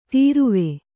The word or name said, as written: ತೊರವಿ